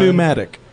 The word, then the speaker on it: pneumatic: Pneumatic. – Well, yeah, it's like naming your kid Pneumatic. – No, you don't pronounce the P in that.